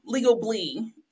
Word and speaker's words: Legably: So not legal, not legally, Legably. – That's correct.